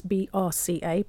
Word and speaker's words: BRCA: That's the way we pronounce BRCA, BRCA, which is breast cancer gene.